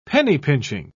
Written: penny-pinching

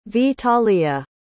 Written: vitaliya

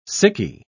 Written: sickie

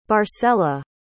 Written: barcella

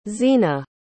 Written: xena

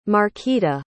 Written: markita

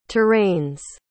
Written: terrains

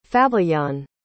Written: fabiyan

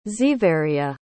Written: xeveria

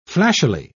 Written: flashily

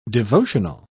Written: devotional

